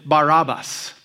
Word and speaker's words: Barabbas: Barabbas, um, we should actually pronounce it Barabbas because it comes from two words, bar and abba, son, father literally, daddy's boy.